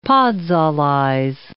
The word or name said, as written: podzolize